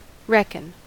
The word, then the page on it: reckon: American English